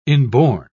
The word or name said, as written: inborn